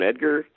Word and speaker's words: Edgar: So uh, my name is Ron Edgar. Last name is spelled E D G A R, so you can stalk me online.